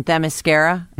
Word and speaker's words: Themyscira: She is an Amazonian princess on Themyscira.